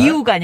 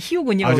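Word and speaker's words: Rio: 아, 리우가 아니라 히우군요. – 아, 이제 현지 발음은 아, 예, 히우입니다. 예, 예, 예.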